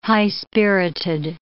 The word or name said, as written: high-spirited